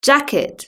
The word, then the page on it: jacket: British English